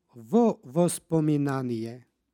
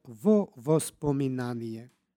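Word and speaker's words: vo vospominanije (во воспоминаніе): između predloga i reči koja počinje istim suglasnikom dodaje se takozvano izgovorno o. Dakle, vo vospominanije.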